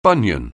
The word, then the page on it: bunion: anglais